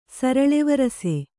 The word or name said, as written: ಸರಳಿವರಸೆ